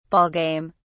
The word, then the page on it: ball game: British English